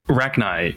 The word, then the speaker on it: Rachni: We're talking about the Rachni. Rachni? Is that the proper pronunciation? Rachni? Rachni? – Rachni. – Rachni? Yeah. Okay, so the Rachni War, the uplifting of the Krogan.